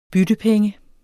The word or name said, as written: byttepenge